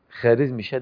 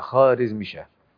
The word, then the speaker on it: خوارزمشاه: وعلى فكرة احنا بنقول خوارزمشاه ده ونطق الصحيح مش خوارزمشاه، لأن الواو دي يسموها الواو الفارسية المعدولة ولا تنطق، تكتب ولا تنطق، يبقى خوارزمشاه. فالدولة الخوارزمية كانت دولة قوية جدا وكانت مرهوبة الجانب، وكان سلطانها بيأثر على الخليفة العباسي في بغداد.